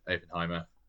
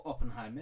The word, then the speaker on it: Oppenheimer: Yeah, I don't think I've ever done double features because obviously in the zeitgeist at the moment is the Barbie Oppenheimer double featurette that people are making. – Or Oppenheimer if you want to do it correctly.